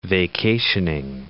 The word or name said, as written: vacationing